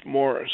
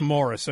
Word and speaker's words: Maurice: Hey, by the way, it's not Maurice, it's Morris. – It's Morris, okay. Oh. Yeah, it's Maurice White, it's Maurice Gibb. – That's correct. – Right, okay. – Really? – And, yeah. And it's spelled Maurice.